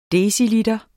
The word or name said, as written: deciliter